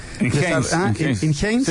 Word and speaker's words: Enhanced Games: Enhanced Games, los juegos dopados que se están... – Enhanced.